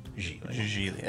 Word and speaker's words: Gigli: That is Gigli. – It rhymes with really. As they'll remind you. – Gigli, I only have 8 notes for.